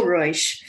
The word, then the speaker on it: Ruysch: So I have another Dutch painter, and that is Rachel Ruysch. – Oh, wow. – Rachel Ruysch.